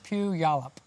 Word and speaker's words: Puyallup: Like church pew, Yallop. – Yeah. – Strange. – But it's spelled like P-U-Y.